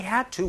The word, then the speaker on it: had to: 자, 여기에서 지금 제가 had to라고 발음하지 않고 그렇죠, had to 이렇게 하는 이유는 D와 T 소리가 연결이 되면요 D 소리가 살짝 사라지면서 had to 이렇게 자연스럽게 연결된다는 것도 같이 알아두시면 좋겠네요.